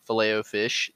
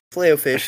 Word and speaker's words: Filet-O-Fish: I feel like you're saying it wrong. – Filet-O-Fish, that's what it is. – Yeah, Filet-O-Fish. – You're saying like Filet-O? – That's what it's called on the menu. It's called the Filet-O-Fish.